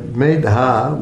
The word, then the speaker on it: Medha: Yeah, and Medha with a long A anyway, yeah.